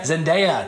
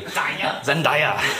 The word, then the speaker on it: Zendaya: I can't remember their names, but I know it's Zac Efron and some girl named Zendaya, I think. How do you pronounce it? – Zendaya. – Thank you. – Zendaya.